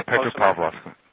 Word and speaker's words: Petropavlovsk: I see Petropavlovsk. Is that how you say it? – Yeah, Petropavlovsk.